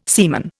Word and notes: seaman: Indian

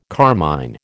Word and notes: carmine: American English

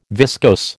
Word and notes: viscose: American English

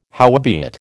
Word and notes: howbeit: American English

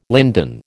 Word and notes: linden: American English

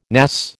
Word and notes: NAS: American English

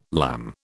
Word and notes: lamb: British English

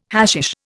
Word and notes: hashish: Indian

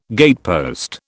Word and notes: gatepost: British English

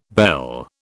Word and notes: belle: British English

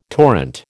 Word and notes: torrent: American English

